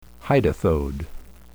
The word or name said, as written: hydathode